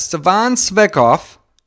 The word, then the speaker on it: Stefan Tsvetkov: And today, I got a special guest, Stefan Tsvetkov.